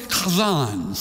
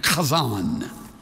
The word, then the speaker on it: chazzan: There was also the chazan, C-H-A-Z-Z-A-N, chazan. When you say it right, something lands on the neck of the person in front of you.